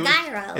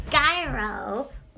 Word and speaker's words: gyro: It's what a gyro. It's what you spend. It's the European dollar. – No. A gyro. Or a gyro, I guess as people pronounce it on the Danforth, are just like chicken in a pita with a bunch of vegetables and stuff.